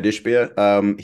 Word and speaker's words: Ishbia: I think Matt Ishbia, is that how you pronounce it? – Yeah. – Matt Ishbia.